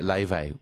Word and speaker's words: Laufey: I'm loving that she's getting into Leve, which is a beautiful singer. Where's Leve from? If you're looking up Leve. – Never heard of Leve. – It's spelled Laufey.